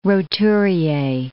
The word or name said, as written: roturier